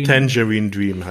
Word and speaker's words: Tangerine Dream: Tangerine Dream heißen die.